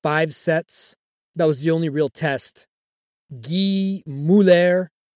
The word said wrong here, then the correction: Gilles Muller: hope I said that right. – Gilles Muller.